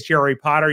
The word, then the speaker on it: Jerry Potter: My name is Jerry Potter. Yes, it rhymes with the boy wizard,